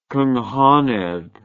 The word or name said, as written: cynghanedd